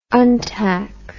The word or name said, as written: untac